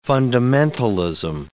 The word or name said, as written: fundamentalism